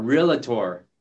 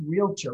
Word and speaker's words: realtor: Some people pronounce realtor wrong. I like to get a little bit of T in it, but you don't want it to be three syllables. You want it to be two syllables. – Okay. – Realtor, not realtor or realtor. Just realtor. – Realtor. – Realtor. Realtor. And the first syllable is longer.